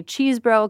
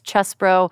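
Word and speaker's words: Chesebro: The other one was Kenneth Chesbro, which everybody keeps wanting to say Cheesebro because he's from Wisconsin, and it looks like it's pronounced Cheesebro, but it's Chesbro.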